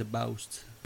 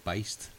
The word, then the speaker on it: Boust: First time, what did you say, Beist? – I think, we said Beist because that's how I heard someone else pronounce it and he didn't correct them. – Yeah. – And then you asked him how it was pronounced and then he said like that, so.